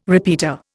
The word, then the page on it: repeater: Indian